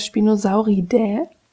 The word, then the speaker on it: Spinosauridae: Spinosaurus ist der namensgebende Vertreter der Spinosauridae, ja, so spricht man das aus, einer Gruppe, zu der auch der Baryonyx, der Irritator und der Suchromimus gehören.